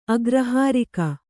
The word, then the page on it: ಅಗ್ರಹಾರಿಕೆ: Kannada